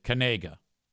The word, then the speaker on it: Knigga: That would be Noah Kanaga of the Eastern Michigan University Eagles, who spells his name N-O-A-H, Noah, and then his last name is spelled K-N-I-G-G-A. And he's a white dude, and he pronounces it Kanaga.